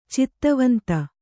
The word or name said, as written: ಛಾತಿವಂತ